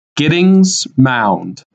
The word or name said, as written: Gittings Mound